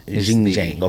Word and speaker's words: Xinjiang: Xinjiang.